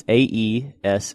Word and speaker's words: Aeschylus: It's Aeschylus.